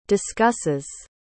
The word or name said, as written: discusses